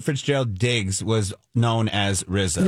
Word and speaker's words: RZA: It's RZA. All right. – I like RZA. – And then Gizza, but it's spelled RZA. – RZA, yeah. But pronounced R-I-Z-Z-A. – Okay, yeah.